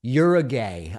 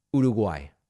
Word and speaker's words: Uruguay: And part of the flavor is Americans pronounce Uruguay in what seem like absurd ways. You're a gay, I found. I, I mean, it's come on. So I'm just, it's just a reminder of the way that it's pronounced Uruguay.